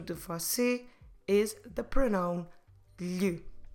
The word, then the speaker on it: lhe: L-H-E. Lhe. I know it's not the easiest sound, but if you remember words that you might have learned before, like son and daughter, filho, filha, these words have the exact same L-H.